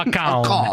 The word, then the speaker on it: account: Quello invece è il mio account. – Oh, lo pronunci allora, bene però, account.